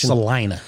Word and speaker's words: Salina: This comes from a listener from Salina, Kansas, or Salina, Kansas. I apologize again about the pronunciation. – I think it's Salina.